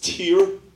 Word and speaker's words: Tyre: She told me it's pronounced Tyre, like a tear from your eye.